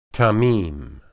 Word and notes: tamim: Hebrew

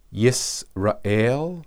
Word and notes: israel: Hebrew

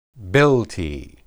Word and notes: bilti: hebrew

